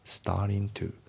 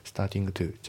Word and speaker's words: starting to: スターリントゥ、スターティングトゥじゃなくてスターリントゥだね。